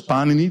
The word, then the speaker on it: Panini: Hij wordt aangeduid als Panini. De klemtoon ligt op de eerste lettergreep. En waarom is hij zo belangrijk voor eh, de geschiedenis van wetenschap?